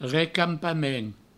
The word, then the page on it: recampament: recampamen